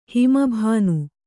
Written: ಹಿಮಭಾನು

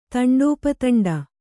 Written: ತಂಡೋಪತಂಡ

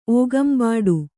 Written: ಓಗಂಬಾಡು

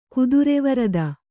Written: ಗೋತ್ರವಿರೋಧ

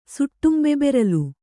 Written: ಸುಟ್ಟುಂಬೆಬೆರಲು

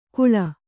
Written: ಕುಳ

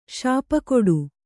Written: ಶಾಪಕೊಡು

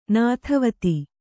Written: ನೆತ್ತಿವತ್ತು

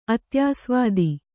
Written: ಅತ್ಯಾಸ್ವಾದಿ

